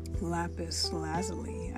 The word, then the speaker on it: lapis lazuli: But it was um, lapis lazuli, I believe is how you say it you got it.